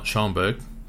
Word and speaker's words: Schoenberg: Having said that, I did like the young lad, how do you pronounce it? Schoenfeld? – Schoenberg. – Schoenberg, sorry.